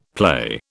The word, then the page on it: play: British English